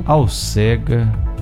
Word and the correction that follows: acelga: Alcega. Acelga. – Acelga. Isso, eu falei errado, né?